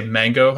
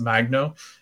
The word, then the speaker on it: Magno: I wanted to say Mango. I always thought that it was Mango, but it's Magno.